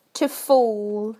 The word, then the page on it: to fall: British English